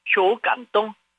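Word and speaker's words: 쇼 깐똥 (受感动): 그래서 수감동인데 발음은 쇼, 그 다음에 감동은 깐똥, 깐똥, 그래서 쇼 깐똥, 쇼 깐똥, 감동받다라는 한자의 중국어입니다.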